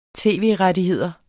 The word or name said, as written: tv-rettigheder